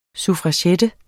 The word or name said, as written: suffragette